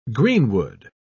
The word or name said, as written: greenwood